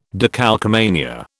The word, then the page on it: decalcomania: British English